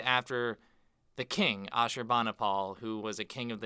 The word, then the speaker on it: Ashurbanipal: The Library of Ashurbanipal. I butchered that. Help me. – The Library of Ashurbanipal. – Thank you.